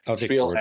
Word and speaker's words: Spiel: Spiel, I think I'm saying that right. I think it's with an SH kind of a sound. – Spiel Essen.